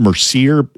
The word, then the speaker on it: Mercier: It's called Mercier. It's spelled M-E-R-C-I-E-R, Mercier Orchard's Hard Cider.